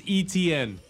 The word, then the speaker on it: Etienne: Travis Etienne. It's undoubtedly the easiest name to pronounce because it's just E-T-N, just the three letters of the alphabet.